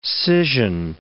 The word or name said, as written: scission